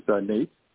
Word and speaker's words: Nate: This is Nate.